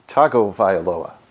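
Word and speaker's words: Tagovailoa: It is Tungo Vailoa. – No, it's Tago Vailoa.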